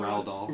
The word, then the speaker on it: Roald Dahl: Roald Dahl? – Roald Dahl.